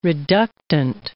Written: reductant

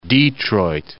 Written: Detroit

